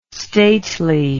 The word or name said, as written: stately